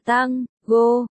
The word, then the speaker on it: tango: Vũ điệu tango tango, đọc là tang, go,